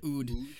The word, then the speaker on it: oud: Oud.